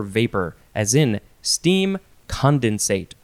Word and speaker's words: condensate: It is the word condensate, and it looks like you can also say condensate if you want to emphasize that syllable. – I want to. – You can. C-O-N-D-E-N-S-A-T-E,